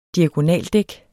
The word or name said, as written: diagonaldæk